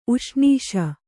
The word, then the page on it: ಉಷ್ಣೀಷ: uṣṇīṣa